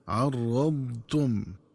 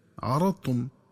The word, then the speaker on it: عرضتم: مراعاة استطالة الضاد عرضتم. ومن الخطأ قراءتهم بفتح الراء وتخفيفها هكذا عرضتم، ومن الخطأ كذلك قراءتها بترك استطالة الضاد وإدغام الضاد في التاء فتصير طاء هكذا عرضتم.